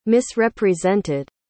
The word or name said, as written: misrepresented